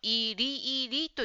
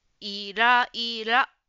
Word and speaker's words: イライラ: 4 番の質問のイライラという言葉ですが、ジムさんはイリイリと言っていました。ちょっと発音が違いましたね。イライラ。イライラです。